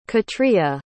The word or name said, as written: katrya